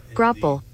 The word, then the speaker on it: graupel: But Grapple. We're having a vocabulary lesson today. It's spelled Grapple. G-R-A-U-P-E-L. – Grapple.